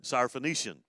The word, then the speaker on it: Syrophoenician: and I said, Syrophoenician.